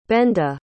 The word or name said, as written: benda